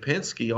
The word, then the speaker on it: Penske: And he's the third guy in line at Penske on that list right there, which is a little surprising to me. – Yeah, the third – hey, you said that right.